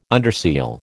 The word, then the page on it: underseal: American English